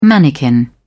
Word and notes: mannikin: British English

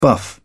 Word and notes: buff: British English

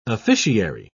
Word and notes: officiary: British English

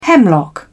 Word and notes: hemlock: British English

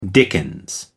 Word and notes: dickens: American English

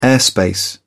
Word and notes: airspace: British English

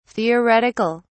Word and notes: theoretic(al): British English

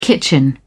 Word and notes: kitchen: British English

